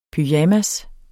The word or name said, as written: pyjamas